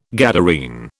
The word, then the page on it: Gadarene: British English